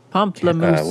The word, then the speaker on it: pamplemousse: Pamplemousse? – Pamplemousse.